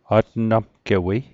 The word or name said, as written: atnamgewei